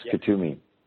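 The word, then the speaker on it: Kuthumi: Is that how you pronounce it? – Yes, Kutumi.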